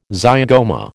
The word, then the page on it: zygoma: American English